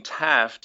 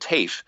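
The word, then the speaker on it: Taaffe: And so the name Taff, T-A-A-F-F-E, I just said it the way we say it, where I come from near Galway, but the student that we have here pronounces it TAFE.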